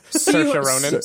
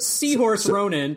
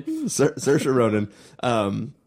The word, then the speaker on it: Saoirse Ronan: Saoirse Ronan. – Seahorse Ronan. – Saoirse Ronan.